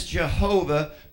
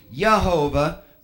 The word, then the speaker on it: Jehovah: One of our modern pronunciations of that is Jehovah, but there's no J in that language. Correctly, it would still be Yehovah.